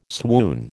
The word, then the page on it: swoon: American English